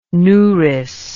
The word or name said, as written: nourice